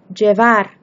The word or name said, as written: llevar